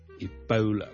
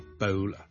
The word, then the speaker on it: Ebola: 最近咧，如果大家有睇国际新闻咧，都应该会留意到 Ebola 呢个伊波拉病毒咧，喺某啲地方肆虐。 Ebola。伊波拉病毒呢，我哋虽然广东话我哋译住伊波拉波啦，我哋嘅 O 那个发音，但如果英文呢，我哋通常呢系读 Ebola 嘅，Ebola，煲煲，好似弓箭嘅弓嗰个煲，OK，咁所以呢唔系 Ebola，不 Ebola， Ebola。